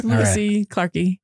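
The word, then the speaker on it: lewisi clarkii: Is that how you say it? Or is it a long I? – That works. Lewisi Clarki. Yep.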